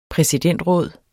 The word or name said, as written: præsidentråd